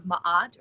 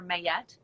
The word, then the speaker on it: Ma'at: And if your heart is as light as the feather of Ma'at or Mayet, it depends on how you want to pronounce it, then you can pass through to the next life.